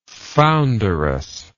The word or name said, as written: founderous